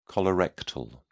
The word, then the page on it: colorectal: British English